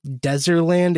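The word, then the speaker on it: Dezerland: Like Deezerland, it could be too, but it's literally spelled with a Z. So it's okay. Like D-E-Z-E-R.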